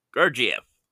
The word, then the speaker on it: Gurdjieff: Opensky, who attributes it to his teacher, the Greek-American occultist Georges Gurd. Anyways, it's a hard to pronounce name. Gurdjieff. Occultist George Gurdjieff considered the Enneagram a symbol of the cosmos, and he never made any connection with it to personality types.